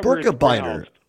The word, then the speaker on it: Birkebeiner: Hey, by the way, I wanted to ask you, how do you pronounce it? The Birkebeiner? The Birkebeiner? – Birkebeiner. Yeah. I assume it's Swedish or Norwegian. Pardon me, Norwegian. – Okay. Yeah. Oh, okay. – So yeah, Birkebeiner. Some people say Berkey. Oh. I saw it's Birkebeiner, but I think most people here pronounce it Birkebeiner.